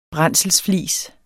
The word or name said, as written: brændselsflis